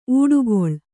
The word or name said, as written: ಊಡುಗೊಳ್